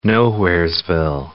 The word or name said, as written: nowheresville